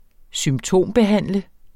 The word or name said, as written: symptombehandle